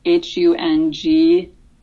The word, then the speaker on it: Hungaricana: Yeah, so Hungaricana or Hungaricana, it's Hungaricana. – Okay. – So kind of spelled like Hungaricana.